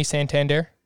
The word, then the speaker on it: Santander: Austin Hayes or Anthony Santander? – Is it pronounced like the bank?